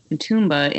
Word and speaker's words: Katoomba: Very good pronunciation on the Australian names there. So I thought Katoomba might. – Okay, good. – I thought Katoomba might trip you up, but it was perfect.